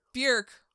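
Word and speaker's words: Björk: Actually, in the interview, I may have failed to mention, she said it's Bjork rhymes with jerk.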